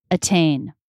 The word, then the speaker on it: attain: So if we're talking about beginning a stressed syllable, then it's not a flap T. That is a true T. Attain, attack.